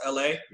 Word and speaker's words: Elhae: Um, you spell it E L H A E, but it's pronounced L A.